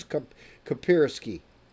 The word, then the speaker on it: Kaspersky: was this this claim by Kaspersky that is it Kaspersky? Kaspersky? Now is that a Russian? Is that a company, individual company, or is that the Russian government? What is Kaspersky?